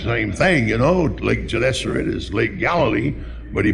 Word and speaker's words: Gennesaret: And he said, Gennesaret.